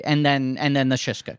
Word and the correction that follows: Shiksa: comes in. – Shiksa. It's incredible. – I keep saying it wrong.